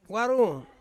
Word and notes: warung: Ansus

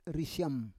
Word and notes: risyam: Roon

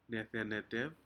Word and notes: nedeneter: Aneityum

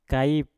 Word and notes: kaip: Mpur

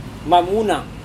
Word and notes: mamuna: Serewen

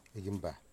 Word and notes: igenba: Wamut

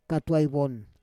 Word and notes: katuai von: Roon

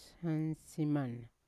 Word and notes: sansiman: Yawa

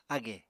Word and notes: ágè: apatani

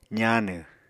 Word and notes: zaanw: apatani

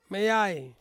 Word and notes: meiai: Ansus

